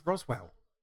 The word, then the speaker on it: Roswell: You just said Rockwell. – I said Roswell. – No, you said... Okay, you just confused me. – I didn't say Rockwell. Well, maybe I did, but I mispronounced Roswell if I did.